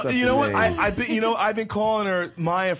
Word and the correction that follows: Mia: for the longest, and she just pronounced it in an introduction a moment ago, and I just found out that I've been mispronouncing it this whole time. – Right. Okay. Hi, guys. I'm Mia Leilani. – Mia. – M-I-A, Mia.